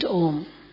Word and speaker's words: Om: So at the beginning, what they used to do is they used to just chant Om. That's all. So you like to chant with me? Let us chant together. And it's a long O. And then again, O. So when Ma is said, then the lips close.